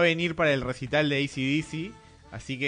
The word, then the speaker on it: AC/DC: No, ACDC. Con acento en la C.